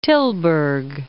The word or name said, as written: Tilburg